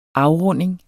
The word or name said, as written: afrunding